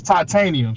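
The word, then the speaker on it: Ti2anium: My name is Titanium, so it's spelled kind of weird.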